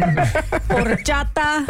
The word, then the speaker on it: horchata: You did great, except your pronunciation of Spanish words is lacking. Horchata. – Horchata. – Horchata.